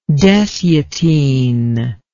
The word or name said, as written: dessiatine